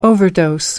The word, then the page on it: overdose: American English